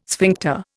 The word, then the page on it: sphincter: Indian